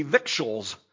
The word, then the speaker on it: victuals: It's not spelled that way. V-I-C-T-U-A-L-S would be victuals. And I looked it up. I thought, man, I don't want to say this wrong. That's how you pronounce that, victuals.